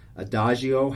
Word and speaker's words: Adagio: Adagio has the soft G.